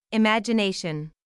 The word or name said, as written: imagination